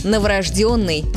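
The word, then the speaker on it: новорождённый: Но норма другая – новорожденный с ударением на «ё».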